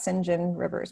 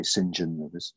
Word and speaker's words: St. John Rivers: So his name, it's spelt St. John Rivers, but I believe we say St. John Rivers, so I'm going to say that.